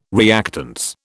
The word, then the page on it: reactance: British English